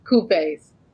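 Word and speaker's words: cuvée: Coupes. – Coupes. – Coupes. C U V E E. Coupes.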